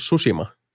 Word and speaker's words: Tsushima: Ghost of Tsushima vielä, anteeksi, piti lausua Ghost of Tsushima, koska näin, näin tekivät. Niin. The T is silent.